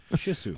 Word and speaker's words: Shih Tzu: You need a Shih Tzu. – Mm-hmm. – Is that how you say it? – Pretty close. – Mm-hmm. Yeah. – Shih Tzu.